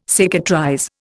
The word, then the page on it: cicatrize: Indian